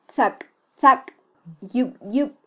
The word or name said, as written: tzaktzakyubyub